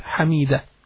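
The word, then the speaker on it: Hameeda: Hamida.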